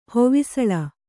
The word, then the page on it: ಹೊವಿಸಳ: kannada